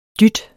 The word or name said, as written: dyt